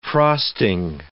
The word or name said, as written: frosting